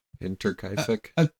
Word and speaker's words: Hinterkaifeck: Hinterkaifeck? – Yeah, F-E-C-K. – Hinterkaifeck? The Hinterkaifeck murders.